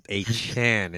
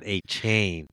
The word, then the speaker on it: Achane: Yes, you have got to get his name correct. It's Achane. It's not Achane. We were mispronouncing his name, Achane.